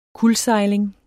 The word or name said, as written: kuldsejling